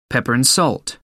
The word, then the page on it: pepper and salt: American English